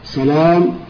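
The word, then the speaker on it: سَلَام: وللمختلف والمختلف صور متعددة منها: واحد، المختلف في صورة حروفه والمختلف في شكله مثل سلام وسلام، الأول بفتح المهملة وتخفيف اللام، والثاني بفتح المهملة وتشديد اللام.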